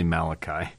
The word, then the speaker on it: Malachi: On the docket today is the first chapter of Malachi, or Malachi. No, definitely Malachi. Malachi, I guess you could say in Hebrew. I'm just looking at the first verse here. Malachi would be the way you pronounce that.